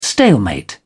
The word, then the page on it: stalemate: British English